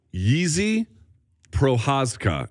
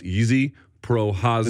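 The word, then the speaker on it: Jiří Procházka: It's spelled J-I-R-I, but it's got all kinds of squiggly lines on the top of it. I am told it is pronounced Yizi Prohazka.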